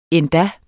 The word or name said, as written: endda